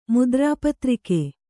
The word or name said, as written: ಮುದ್ರಾಪತ್ರಿಕೆ